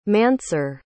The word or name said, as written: Mansour